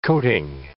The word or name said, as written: coating